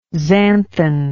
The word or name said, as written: xanthan